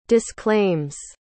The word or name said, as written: disclaims